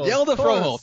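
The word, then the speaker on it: Hjalte Froholdt: Oh, Yelda Froholt, thank you.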